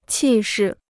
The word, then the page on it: 气势: qì shì